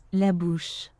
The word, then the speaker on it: la bouche: In Französisch, da wird die wohl so ausgesprochen. Wir lauschen mal. Ich nehme mal das Bett extra runter, damit es aufmerksamer wird, also deutlicher wird. Ne? Nicht, was das Bett im Hintergrund hört. Ich ziehe es mal runter und dann lauschen wir mal, wie das ausgesprochen wird.